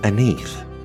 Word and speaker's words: Aneth: Dill in French is pronounced Aneth and spelt A-N-E-T-H.